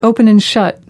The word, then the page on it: open and shut: American English